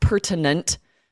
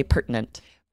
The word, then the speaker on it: pertinent: It looks like it should be pronounced pertinent, but we really don't. We use that glottal stop and say pertinent.